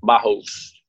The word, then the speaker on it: Bajos: Is it Bajos or Bajos? – It is. Oh my God, the J is like an H. – Okay, so Bajos. – Bajos.